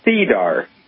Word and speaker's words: SEDAR: In Canada, there is CDAR.